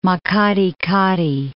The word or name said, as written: Makgadikgadi Pans